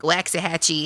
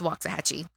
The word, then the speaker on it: Waxahachie: Because he was saying like Waxahachie. And I'm like, no. We don't say Waxahachie. It's Waxahachie. Okay.